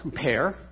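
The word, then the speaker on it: huper (ὑπέρ): It's a Greek word. It's pronounced huper, and it means in the place of, in the benefit or for the benefit of.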